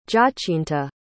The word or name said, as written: giacinta